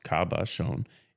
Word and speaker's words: cabochon: A cabochon, pronounced cabochon, is defined as a gemstone that has been shaped and polished rather than faceted. The term comes from the French word caboche, meaning head or noggin.